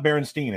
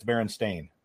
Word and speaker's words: Berenstain: It, it's not Berenstein. It's Berenstain. – S T A I N.